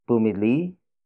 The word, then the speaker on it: pumili: Pumili si Kuya Rem ng laruan para sa anak niya.